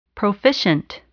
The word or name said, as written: proficient